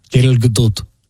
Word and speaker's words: Gal Gadot: That's how to say it with an Israeli accent. – Gal Gadot. – Gal Gadot.